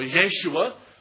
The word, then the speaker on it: Yeshua: Jesus, as I'm sure we know, comes from the Hebrew, and I'm pronouncing two alternatives here, either Yehoshua or Yeshua, which we know in the Hebrew is Joshua when it comes to the translation in English.